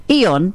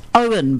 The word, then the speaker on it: Eoin: The name's spelled E-O-I-N, by the way. – Yes. And, of course, it's, according to the blurb on the black, Ian, pronounced Owen, by the way, Colfer was born and raised in Wexford, Ireland.